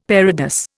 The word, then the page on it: parados: Indian